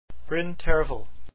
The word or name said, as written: Terfel